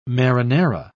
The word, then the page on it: marinara: British English